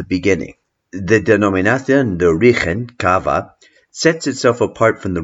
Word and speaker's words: Cava: C-A-V-A, in Spanish pronounced Cava because the V is pronounced like a B.